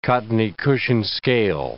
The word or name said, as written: cottony-cushion scale